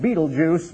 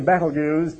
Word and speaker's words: Betelgeuse: Well, a star, how about, some people want to pronounce it Betelgeuse, but it's really Betelgeuse, perhaps,